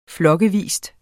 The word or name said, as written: flokkevis